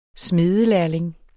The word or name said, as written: smedelærling